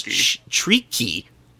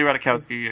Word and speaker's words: Ratajkowski: How do you say her last name? – Ratajkowski. – I thought it was Treaky.